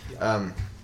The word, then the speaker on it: Branagh: Kenneth Branagh. Branagh. I don't know how to say his name. – Yes. – Yeah. – Kenneth Branagh.